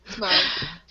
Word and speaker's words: Smaug: Smaug. – Smaug. Smag.